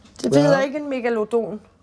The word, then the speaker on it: megalodon: Jeg plejer at bruge de latinske udtaleregler. – Mm. – Så hedder den megalodon. Men af en eller anden grund, så har vi danskere bare lyst til at sige megalodon, eller megalodon.